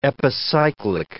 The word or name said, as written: epicyclic